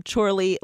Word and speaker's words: Chorley: The other voices you heard was Chorley FC, which they just won they are a football club from England, from Chorley, Lancashire.